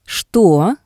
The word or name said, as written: shto